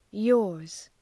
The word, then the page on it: yours: jɔ:rz